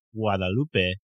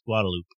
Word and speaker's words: Guadalupe: The G has, you know, like guacamole. So it'd be mm-hmm. like Guadalupe would be closer. And then I'm sorry, my pronunciation is not near where it should be. But it sounded great to me, a layperson. – But everyone around there conventionally says Guadalupe.